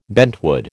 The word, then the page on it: bentwood: American English